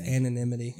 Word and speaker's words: anonymity: I like having I wouldn't say anonymity, but. It's anonymity. I said it right the first time.